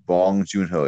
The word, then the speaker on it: Bong Joon-ho: I want to say Bong Joon-ho. – That sounds like a name I've heard. – Let me look really, really quick. Bong Joon-ho.